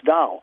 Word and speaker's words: Tao: That's the meaning of the Chinese word Dao, T-A-O, pronounced Dao.